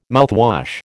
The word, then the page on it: mouthwash: American English